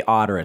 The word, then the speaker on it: Otter: And we should say Otter. It's O-T-T-E-R. – That's right. – AI. – My California accent. Yeah. People are probably thinking it's O-D-D-E-R or something like that.